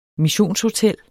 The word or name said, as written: missionshotel